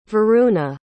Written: varuna

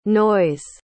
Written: noyes

